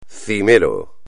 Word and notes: cimero: espagnol

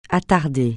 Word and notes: attarder: francais

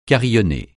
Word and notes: carillonner: francais

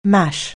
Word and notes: mash: anglais

